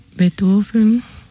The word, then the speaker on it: Beethoven: Bach, Beethoven. – Uh-huh. Beethoven, lo pronuncia en alemán. – En alemán, sí.